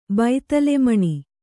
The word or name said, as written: ಬೈತಲೆಮಣಿ